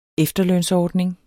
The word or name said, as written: efterlønsordning